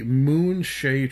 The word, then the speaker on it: Moonshae: This is a book by Douglas Niles, and it is called Darkwalker on Moonshae, and that is M-O-O-N S-H-A-E, Moonshae. I do believe that would be how you pronounce it.